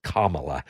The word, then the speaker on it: Kamala: Kamala Harris, actually it's Kamala, but I mean, nobody can say it right, not even her.